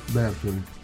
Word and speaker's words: Bertoli: Perché tu hai scelto questo pezzo di Pierangelo Bertoli o Bertoli, non mi ricordo, la pronuncia c'è l'accento spostato. – Bertoli.